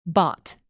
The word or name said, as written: bought